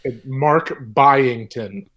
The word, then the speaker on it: Byington: Mark Byington. – Buying, so like I am buying a jacket, but with 10 on the end of it.